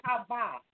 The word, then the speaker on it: Chavah: It was called Hava. It's spelled C-H-A-V-A-H.